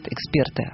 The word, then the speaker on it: эксперт: Они все считают, что ударение в слове «эксперт» должно ставиться на второй слог, на гласную «е».